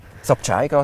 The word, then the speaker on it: Sabchaiga: Sabchaiga, die Suche nach dem Palliativ-Care-Paradies. Habe ich es richtig gesagt? – Sabchaiga, die Suche nach dem Palliativ-Care-Paradies. Genau. Ja, genau.